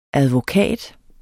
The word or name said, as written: advokat